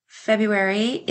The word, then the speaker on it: February: February is one of the most misspelled words because there is a silent R in it for some reason.